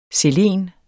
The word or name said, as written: selen